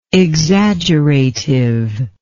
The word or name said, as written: exaggeratively